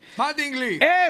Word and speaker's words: Mattingly: Mattingly.